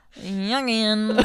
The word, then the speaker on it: Jungian: That was the wrong emphasis on the wrong syllable. Emphos? I said that totally wrong. – Jungian.